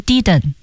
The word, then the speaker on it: didn't: 那我记得以前连读教过怎么读，那为什么这次老师没有怎么读呢？他读成了个 didn't。 – 可选啊，这是这个 didn't 这一个字呢，我们可以就省结尾的 t Didn't，我们也可以把中间的的也给省了，用一个喉音停顿，didn't，两个都会见到的，两个都对。